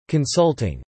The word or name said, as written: consulting